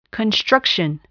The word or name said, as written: construction